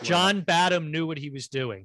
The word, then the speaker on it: Badham: I think it's distant enough that it's like, Oh, by the way, I pronounce his name Bad Ham. – Oh, but I see Badham, he's English, and you're probably right. – I think you're right. I think it's Bad Ham.